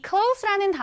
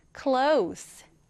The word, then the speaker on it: close: C-L-O-S-E. 자, 그런데 이 단어는 조금 특이한 점이 있어요. 이 close라는 단어는 닫다 라는 동사로도 쓰이는데요. 그런데 어떤 의미로 쓰이느냐에 따라서 발음이 달라집니다. 자, 여러분, 가까운 혹은 가까이 이라는 의미로 쓰일 때는 close, S 발음이 나요. 얘가 S 발음이 나요. 그런데 이 close가 닫다라는 의미로 사용이 될 때에는 close가 아니라 close, Z 발음이 나게 됩니다. 여러분 구분하실 수 있겠죠? 우리 닫다일 때만 close, 가까운 가까이 라는 의미로 쓰일 때는 close가 되는 거예요.